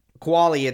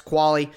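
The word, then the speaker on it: Qvale: Kuali, it's Q-V-A-L-E, so maybe my pronunciation. I checked on the pronunciation, though. I have it right. It's Kuali, but Either way, he's a guy coming over from Houston, 30 years old.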